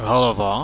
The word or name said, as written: halava